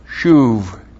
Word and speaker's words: shuv: Now, that verb to turn back is the Hebrew verb shuv. S-H long U-B, shuv.